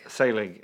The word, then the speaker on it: Saelig: Selig. It's spelled S-A-E-L-I-G.